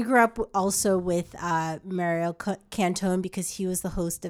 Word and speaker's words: Cantone: Cantone.